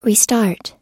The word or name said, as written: restart